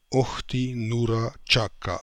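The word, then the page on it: Okhti Noora Chaka: Russian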